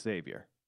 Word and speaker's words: Xavier: You don't pronounce it, just Xavier.